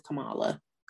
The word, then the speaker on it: Kamala: How do I say her name? – I believe it is Kamala. – Kamala, right? Uh-huh.